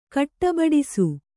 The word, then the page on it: ಗಡಬಡಿಸು: gaḍabaḍisu